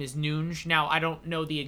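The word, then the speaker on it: Núñez: We'll save that for another time, but let's get back to Darwin Nunes. – Is that how you pronounce it? Is it like the Bruno Fernandes? – Yeah.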